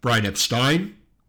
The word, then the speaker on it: Brian Epstein: If you're ever worried about how to pronounce his name, it's Brian Epstein.